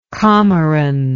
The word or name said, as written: comoran